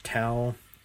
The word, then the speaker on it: Tao: And be sure to note, Tao of the Day is spelled Tao of the Day, T-A-O, O-F-T-H-E-D-A-Y. It's spelled Tao. It's pronounced Tao. Don't ask me why, it just is.